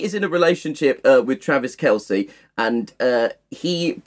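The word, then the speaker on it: Travis Kelce: How do you pronounce this man's surname? Is it Kelsey? – Travis Kelsey. – Travis Kelsey, right.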